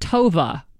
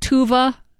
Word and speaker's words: Tove: Her now 8-month-old daughter is named Tova, but it's spelled T-O-V-E. In Sweden, they pronounce it Tuva.